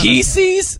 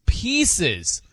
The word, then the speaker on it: Pieces: Yeah, that's Pieces? He hit me with that. And I'm like... I'm like, how do you say it? He's like, Reese's Pieces. You don't say pieces. You say pieces.